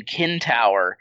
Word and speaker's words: centaur: And my professor, he pronounced centaur with the ancient Greek hard K sound at the end. So he kept saying kentaur. And so I have in my notes like KEN-TOWER ? – Yeah.